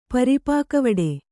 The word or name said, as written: ಪರಿಪಾಕವಡೆ